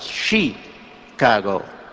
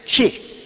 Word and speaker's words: Chicago: Once I was listening to him on television talking about the great city, our great city of Chicago, and he said, you don't pronounce it Chicago, it's chi, like chick, you know, or like chicken, Chicago. And ever since then, I try to remember to pronounce the name correctly, Chicago.